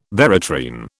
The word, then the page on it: veratrine: British English